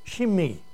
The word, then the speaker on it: Shimei: It's an E. It's a long E. Shimei.